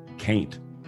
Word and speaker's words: Kaint: And I say Kaint intentionally. – Oh, yeah. – I pronounce it Kaint so that it can rhyme with Haint, because that's also how I talk sometimes.